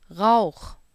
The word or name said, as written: Rauch